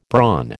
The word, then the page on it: brawn: American English